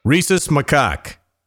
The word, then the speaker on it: Rhesus Macaque: I'll spell it for you. R-H-E-S-U-S. Maybe it's Rhesus. And then it's M-A-C-A-Q-U-E. – No, it'd be Rhesus, but... – Rhesus Macaque. – Yeah, that's it.